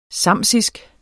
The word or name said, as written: samsisk